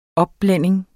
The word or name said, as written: opblænding